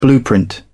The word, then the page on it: blueprint: British English